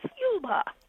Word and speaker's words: Yuleba: When I first came out here, I thought Y-U-L-E-B-A was Yuleba. And I found out it's Yulba. Depends on where you put the pronunciation.